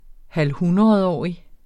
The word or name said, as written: halvhundredårig